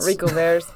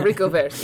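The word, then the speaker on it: haricots verts: We're going to call them pork codverts. – Rico verts, yeah. – Is that how you say that? – Rico verts, yeah.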